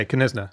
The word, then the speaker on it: Knysna: I'd guess Knysna, I think. – Okay, Knysna.